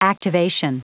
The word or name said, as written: activation